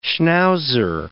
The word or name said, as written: schnauzer